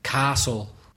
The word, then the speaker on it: castle: Castle. – Castle, yeah. That's castle. It. Castle. Castle's got the silent T.